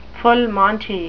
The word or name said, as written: full monty